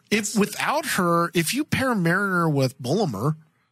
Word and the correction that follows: Boimler: Boimler. Think Boimler. – Of Boing.